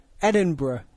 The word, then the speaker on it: Edinburgh: How do you say it? – Edinburgh. – It's spelled Edinburgh, though, right? – I know it's spelled Edinburgh. But that's not how you say it.